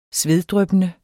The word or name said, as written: sveddryppende